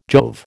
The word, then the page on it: Jove: American English